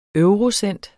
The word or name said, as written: eurocent